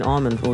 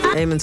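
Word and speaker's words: Amon: I've been saying Armand all these years and I just actually Googled the pronunciation. Eamon Tobin on that last one.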